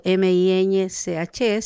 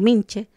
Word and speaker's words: miñche: Miñche, ¿cierto? Que uno la leería como minche, pero no es minche, sino que la ñ, ¿cierto?